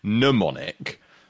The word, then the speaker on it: mnemonic: We've come up with a mnemonic, which is M-N-E-M-O-N-I-C. The rarest occasion, the first occasion, I think, of seeing a silent M.